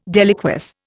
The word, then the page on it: deliquesce: Indian